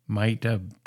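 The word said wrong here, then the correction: Myti: I believe that's pronounced Mita. Mighty. It's M-Y-T-I. I don't know why I put an A on that, but mighty.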